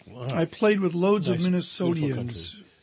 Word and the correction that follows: Minnesotans: in college. – Minnesotans? – Minnesotans, is that how you say it? – Yeah. They got to say it like they do, Minnesotans.